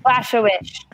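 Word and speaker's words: Błachowicz: It's Adesanya and Blachowicz? How do I say his name? Mm-hmm. – Blachowicz.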